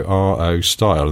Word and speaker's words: Rostyle: They were called Rubri Owen Style Wheels, so R-O Style, and then they just shortened it to Rostyle. So, you know, pronunciation-wise, it should be Rostyle.